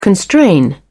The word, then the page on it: constrain: British English